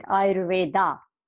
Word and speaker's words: Ayurveda: First of all, the word Ayurveda, it's like because of the Sanskrit and its spell, A-Y-U-R-V-E-D-A. So we just pronounce it Ayurveda. Actually, the word is Ayurveda.